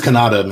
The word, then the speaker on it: Kanata: Canada. – It's Kanata, man. That's how you say it. Kanata. No, it's for how you say it, man. That's how they're saying in the movie.